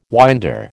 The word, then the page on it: winder: American English